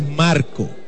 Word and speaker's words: Marco: Y como dice Don Mendy, no es que lo estemos pronunciando mal. – Es Marco, sin la S.